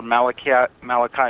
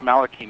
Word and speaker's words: Malachi: How this book came to be written was in 1996, I met with Father Malachi Martin, sometimes pronounced Malachi Martin, in New York.